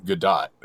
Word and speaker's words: Gadot: The sequel to Wonder Woman, that hit movie with Gal Gadot. And this is a follow-up to her last movie, Singing Imagine in Front of a Porch. – Godot? Gal Godot? Godot, actually, is, I think, how you say it.